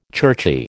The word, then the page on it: churchy: American English